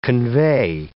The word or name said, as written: convey